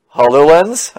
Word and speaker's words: HoloLens: In fact, so I was going to say they also announced, I can't say this word very well, HoloLens, I think is what it's called.